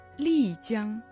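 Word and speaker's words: Лицзян (丽江): Лицзян. Там Ли произносится в четвертом тоне.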